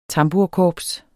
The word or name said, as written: TAMBURKORPS